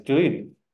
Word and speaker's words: grün: Make sure you pronounce the N at the end. Grün.